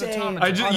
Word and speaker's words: automata: Yeah, the correct pronunciation is automata.